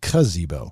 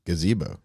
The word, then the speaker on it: gazebo: You know, you said cuzebo. – Gazebo. – Gazebo. – That's what I'm saying. – You're saying Gazebo. – No, I'm not. – Say ga like gazelle. – Gazebo. – Yeah, now you said it right.